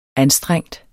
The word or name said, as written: anstrengt